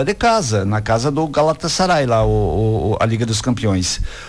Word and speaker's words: Galatasaray: Eu falava que foi prato cheio porque agora à tarde teve o, deixa eu ver se eu consigo pronunciar corretamente o nome do time, o Galatasaray, e bem isso. – Não, ficou bonito.